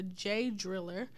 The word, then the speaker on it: J-Driller: We had Tama Tonga versus El Fantasma, with Tama picking up the victory with the J-Driller. – Yes. – I think I'm saying that right. – That's right.